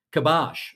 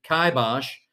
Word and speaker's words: kibosh: And today's Word of the Day is kibosh. Or if we're in the UK, kibosh. It's spelled K-I-B-O-S-H.